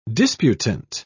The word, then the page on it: disputant: British English